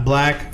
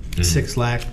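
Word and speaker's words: 6lack: Yeah, he's got Black, who we're going to talk about in a minute. Six Lack, for those phonetically pronouncing it.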